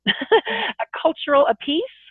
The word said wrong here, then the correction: A Culture Apiece: A Culture Apiece. – So like a one culture apiece. Per person.